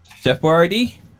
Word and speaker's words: Boyardee: Chef Boyardee. – Okay. All right. Well, I was thinking what you said. I'm very curious now. – I thought he was gonna say Chef Boyardee.